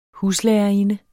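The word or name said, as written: HUSLÆRERINDEN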